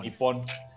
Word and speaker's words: Nippon: Mira, Japón no se llama Japón, se llama Nipón. Nippon.